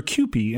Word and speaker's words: Kewpie: I'm glad you pronounced it Kewpie because I didn't know how to pronounce that.